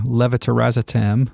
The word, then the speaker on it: levetiracetam: Okay, so it sounds like Keppra or levetiracetam.